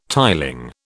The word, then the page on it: tiling: British English